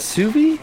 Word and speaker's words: sous vide: A sous vide? – Sous vide. It's spelled Frenchy though. It's S-O-U-S space V-I-D-E. But yeah, so you do that, and it turns the meat. – S-O-U.